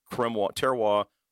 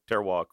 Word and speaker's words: terroir: And then I wanted to do a box press cigar, and funny enough that terroir rhymes with in the same OIR as cremois, terroir, cremois, sorry.